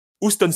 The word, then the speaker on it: Houston: Houston Street, hein, je ne sais pas comment on prononce.